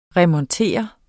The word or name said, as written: remontere